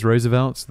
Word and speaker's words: Roosevelt: It is Roosevelt. That's how the man himself would choose to pronounce it, despite the various controversies, despite the 4 pronunciation options that my pronunciation dictionary that I normally go to gives me.